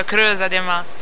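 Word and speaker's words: Crêuza de mä: La pronuncia Creusa de Ma.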